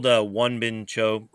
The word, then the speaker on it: Juan Bincho: But I'm told Juan Bincho, and that's how you pronounce it. It's one as in one, okay. Or to what you do to a game, and then bin.